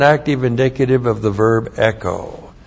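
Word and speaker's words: echo (ἔχω): And then we have echo. E-C-H long O, echo.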